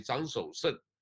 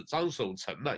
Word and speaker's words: 张守成: 那另外一方面呢，就马上又是这个所谓张守胜，啊，也是张守成了，应该念成了，一个日下的一个成功的成，应该是念成。